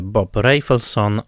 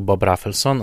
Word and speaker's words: Bob Rafelson: Film w reżyserii Boba Rafelsona W Stanach mówi się Bob Rafelson, ale jakoś naturalniej będzie mi się mówiło po prostu Bob Rafelson, a zatem taką wymowę przyjmuję.